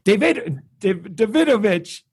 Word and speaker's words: Davidovich: Well, again, Michael Davidovich.